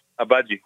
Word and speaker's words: Agbaji: אבאצ'י? אבאג'י, כן. אבאג'י, בלי הג'י, לא מבטאים את ה... כי זה כתוב A-G-B-A-J-I.